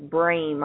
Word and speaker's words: Brame: However, my legal last name is Brame.